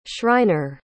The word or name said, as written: shriner